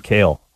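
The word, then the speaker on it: Cale: I'm Kale.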